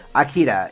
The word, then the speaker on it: Akira: Akira is how you pronounce it.